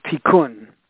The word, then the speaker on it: Tikkun: And the first word is T-I-K-K-U-N, and it's pronounced Tikkun. T-I-K-K-U-N. There's a first word, and it means repair, repair. And the second word is Olam, O-L-A-M. O-L-A-M. So I'll repeat, Tikkun, T-I-K-K-U-N, and the second word is Olam, O-L-A-M. So together, they are pronounced Tikkun Olam.